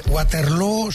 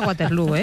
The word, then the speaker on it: Waterloo: Bueno, lo primero, Waterloo se pronuncia igual que Feijóo.